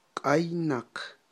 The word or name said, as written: q'aynaq